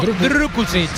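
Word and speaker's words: Drkušić: Hay que pronunciarlo Drukucic. – Drukucic. – Drukucic. – Hay que poner la boca así como si.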